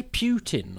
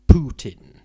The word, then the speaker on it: Putin: But now but in recent years it's become Putin. I mean, for about the last 10 years. – Putin. – I assume because that's nearer to the Russian pronunciation, which it seems to be when you hear people.